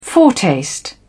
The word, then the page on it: foretaste: British English